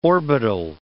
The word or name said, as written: orbital